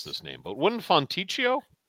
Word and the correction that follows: Fontecchio: help me out here? – Fontecchio. – Tecchio.